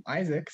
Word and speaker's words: Isaac: Hey, why do I keep pronouncing your name Isak? – I mean, you never pronounce my name Isaac, so I thought you were going to keep going with Isak.